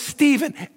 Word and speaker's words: Stephen: Now, my name is Stephen, S-T-E-P-H-E-N.